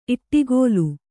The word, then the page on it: ಇಡುಗಲ್: iḍugal